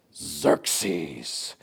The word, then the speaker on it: Xerxes: You have to say it like this. Xerxes.